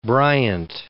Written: Bryant

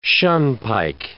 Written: shunpike

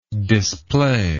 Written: displayer

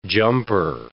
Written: jumper

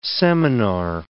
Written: seminar

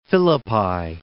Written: Philippi

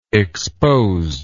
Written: exposedness

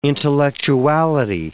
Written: intellectuality

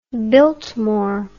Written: biltmore

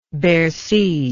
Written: Bercy

